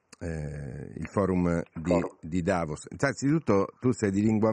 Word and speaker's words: Davos: sulla, sulla, sulla O, cioè l'accento va sulla O, Davos. Gli italiani dicono tutti Davos, non so perché, però è, è Davos.